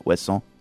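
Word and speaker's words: Ouessant: Am I pronouncing it right? Wesson? – Yeah, perfect.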